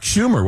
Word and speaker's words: Schumer: I believe that was Chuck Schumer, was it not? – Yes. Yes. The senator's name rhymes with rumor.